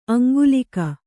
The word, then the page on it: ಅಂಕಲಿಗೆ: aŋklige